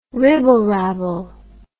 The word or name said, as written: ribblerabble